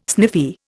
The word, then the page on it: sniffy: Indian